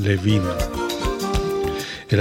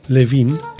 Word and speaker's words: Levine: Y hoy vamos a comenzar con un discazo de un pianista neoyorquino excepcional, Dan Alan Levin. El apellido Levin lo he visto y lo he escuchado pronunciado como Levine y Levin, pero en el caso de Dan Alan Levin, pues es Levin.